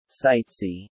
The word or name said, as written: sightsee